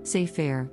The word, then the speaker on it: Sefer: as a noun pronounced as Sefer, meaning book.